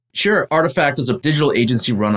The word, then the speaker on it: RTFKT: They acquired a brand, an NFT brand called Artifact, but it's not, it's spelled R-T-F-K-T. So you would never really know that that's pronounced Artifact.